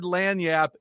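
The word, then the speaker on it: lagniappe: I learned that word Lanyap in Louisiana. That's how I could pronounce it, so pretty proud of myself there.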